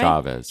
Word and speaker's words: Chavez: Am I saying that right? – Chavez. Cesar Chavez.